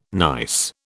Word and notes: nice: British English

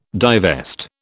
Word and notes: divest: British English

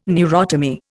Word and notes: neurotomy: Indian

